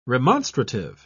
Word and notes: remonstrative: British English

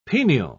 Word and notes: penial: British English

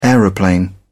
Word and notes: aeroplane: British English

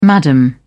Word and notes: madam: British English

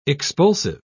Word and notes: expulsive: British English

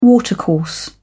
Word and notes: watercourse: British English

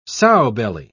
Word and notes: sowbelly: British English

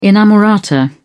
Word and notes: inamorata: British English

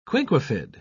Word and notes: quinquefid: British English

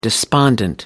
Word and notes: despondent: American English

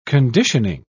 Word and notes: conditioning: British English

